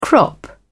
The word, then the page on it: crop: British English